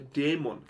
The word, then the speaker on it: Dämon: Der Dämon. – Der Dämon. – Der wird nicht Demon ausgesprochen. – Der nicht Demon? – Der Dämon. Ah. Weil er wirklich D-A-E ausgeschrieben wird.